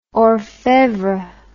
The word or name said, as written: orfevre